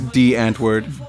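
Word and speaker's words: Die Antwoord: It's D Antwood. – D, so it's like German? – No, well, I don't know.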